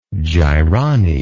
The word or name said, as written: gyronny